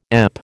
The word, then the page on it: EP: American English